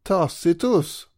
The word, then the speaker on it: Tacitus: Tacitus, ett namn som faktiskt uttalas just Tacitus av expertisen oavsett hur fel det känns.